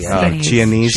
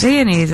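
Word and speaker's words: Chianese: I think it's C-H-I-A-N-E-S-E. – Chinese. – Chinese. – Chinese, isn't it? – What? – Isn't the H silent? Chinese. – Chinese nuts. – Chinese.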